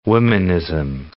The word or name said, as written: womanism